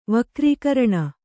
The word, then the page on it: ವಕ್ರೀಕರಣ: vakrīkaraṇa